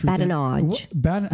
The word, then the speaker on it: badinage: So badinage. – Badinage. – How do you spell it? – B-A-D. – B-A-D.